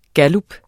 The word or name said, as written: gallup